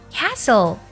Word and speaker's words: castle: t 不发音，像城堡 castle，还有口哨 whistle，都是这样的。